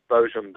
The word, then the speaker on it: Boojum: It's called The Story of Bojum and Annie, and you literally pronounce that Bojum, but it kind of reads like Boojum. That's B-O-O-J-U-M, and it was about a Bigfoot-like creature that appeared near the Eagle's Nest Hotel up here in the mountains.